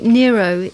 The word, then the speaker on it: Nyro: Well, it seems to be Nyro is the pronunciation. – So it's not like Robert De Niro, then. It's N-Y-R-O. – N-Y-R-O.